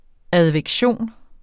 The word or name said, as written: advektion